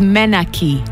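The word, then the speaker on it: menarche: M-E-N-A-R-C-H-E. I would have pronounced it Menarch if I hadn't looked up how to pronounce it.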